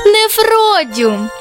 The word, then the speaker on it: Нефродіум: Як? Мефодіум? – Та ні, Нефродіум гірський.